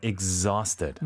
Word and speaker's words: exhausted: 발음이 중요한 게 exhausted. – 이그저 아니고 exa가 되는군요. – Right. My feet are exhausted.